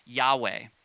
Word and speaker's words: Yahweh: We actually don't understand perfectly the pronunciation of this, but our best interpretation is the word, or the high name, Yahweh.